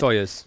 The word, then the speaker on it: Soyuz: Soyuz.